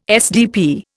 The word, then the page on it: SDP: Indian